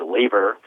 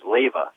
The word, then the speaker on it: Laver: And I always thought it was Laver phonetically, and he said it was Lava.